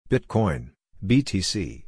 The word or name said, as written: Bitcoin (BTC)